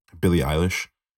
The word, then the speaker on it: Billie Eilish: Billie Eilish.